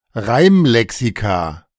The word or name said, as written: Reimlexika